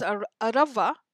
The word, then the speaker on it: Arava: The word actually is Arava, okay, A-R-A-V-A, with an accent on the top of the A.